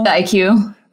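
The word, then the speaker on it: iQue: Nintendo Q? Is that how you pronounce it? The Chinese console? – The IQ. – The IQ. Okay.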